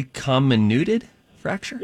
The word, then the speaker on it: comminuted fracture: Okay, so first of all, are we saying it correctly? Comminuted fracture? – Yes, you are.